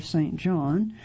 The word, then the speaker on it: St. Jean: No problem there, except his wife, fellow high-level spy Catherine St. Jean, or St. John, it's spelled J-E-A-N, Kate Blanchett, to whom he's devoted, is on the list.